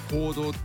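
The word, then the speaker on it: 黄道: ちょうど 27 土曜日はサソリ座の一等星アンタレスのそばにね輝くので、こうしてこうやってね、乙女座、天秤座、サソリ座と渡っていくお月様の道、太陽の通り道は黄色い道と書いて行動っていうじゃないですか。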